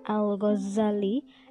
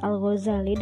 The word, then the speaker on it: Al-Ghazali: Kata Al-Ghazali kadang-kadang diucapkan dengan Al-Ghazali dengan dua Z Kata ini berasal dari Ghazal yang berarti tukang pintal benang karena pekerjaan ayah Al-Ghazali adalah memintal benang wol. Kadang-kadang juga diucapkan dengan Al-Ghazali dengan satu Z yang diambil dari kata Ghazalah, nama kampung kelahiran Al-Ghazali. Yang terakhir inilah yang banyak dipakai.